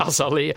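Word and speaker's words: Azealia: Er zijn nog dingen die uh, Ja, we gaan moeten aan Azialia Banks eh, vragen, denk ik. Eh, of ze nog samen zijn. – Azalea. We zijn die haar naam echt al twintig keer verkeerd aan het uitspreken.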